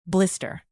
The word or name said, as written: blister